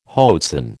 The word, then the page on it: hoatzin: American English